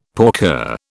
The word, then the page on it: porker: British English